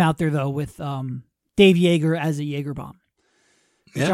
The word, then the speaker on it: Joerger: J-O-E-R-G-E-R. Pronounce Jaeger, like yay. Ger, or like a Jaeger bomb.